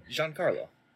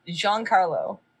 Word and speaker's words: Giancarlo: My Canadian tongue cannot say that name. – Giancarlo. – Oh. Is that how you say that? – Yeah, Giancarlo. – Giancarlo. – Yeah. – So you say it like it's two names, but it's one.